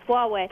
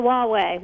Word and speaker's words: Huawei: Yeah, my way or the Huawei. Huawei. It took me a while to know how to pronounce that, too. I think I was in China for like 3 days before I got it right.